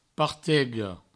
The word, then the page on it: partega: partego